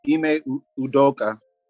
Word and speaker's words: Ime Udoka: Now it's Ime Udoka.